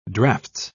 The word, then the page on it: draughts: British English